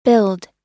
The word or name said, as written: billed